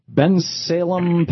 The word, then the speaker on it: Bensalem: Anyhow, Frank in Bensalem, Pennsylvania. Am I saying that right, Frank? – Yes, you are.